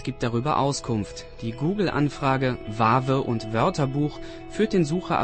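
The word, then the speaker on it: Wawe: Vave heißt die Band und sie kommt aus Tschechien. Das ist eine Überraschung, denn Vave schreibt man mit W am Anfang und W in der Mitte. Und W ist ein Buchstabe, der in der tschechischen Sprache eigentlich nicht verwendet wird.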